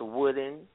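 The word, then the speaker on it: Woden: Pronounced wooden, was the Germanic version of the pagan god Mercury, and they named the day Wooden Day, from which we get Wednesday.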